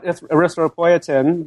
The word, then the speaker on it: erythropoietin: It's pretty close. I call it erythropoietin, but there are other pronunciations out there.